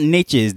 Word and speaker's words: niche: And even in this whole NFT landscape, there's different niches, there's different pockets, or I should say niche, the proper pronunciation.